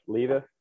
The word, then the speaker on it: Levis: I think it's Levis. – Levis.